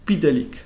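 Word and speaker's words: Mxyzptlk: Hay un simple debate de cómo se dice Mr. yo lo digo como supuestamente se dice, supuestamente obligatoriamente se dice, que es Mr. Mrs. Spitalik, que era una especie de enanito de la quinta dimensión que le hacía, le hacía bromas a Superman, desafíos, como que le tomaba.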